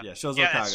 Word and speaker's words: Shozo Kaga: Shozo Kaga.